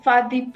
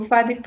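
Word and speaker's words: Bathi: It was called Fadi Primary School, but it's spelled with a B. But in Kikuyu, we pronounce B as F. So Fadi Primary School.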